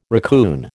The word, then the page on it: racoon: American English